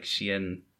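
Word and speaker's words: Cienfuegos: I'm pretty sure it's like, it's a soft C, so it's like Cienfugos.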